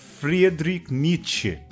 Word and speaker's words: Friedrich Nietzsche: Esse texto maravilhoso que acabamos de ouvir é do filósofo, me corrija se eu pronunciar errado, oh Alexandre, Friedrich Nietzsche. É isso? – É, tá bom, esse daí é o sotaque do norte da Alemanha, né?